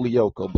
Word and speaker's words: Lyoko: It's, it's, it's, pronounced Code Lyoko, but yes, of course.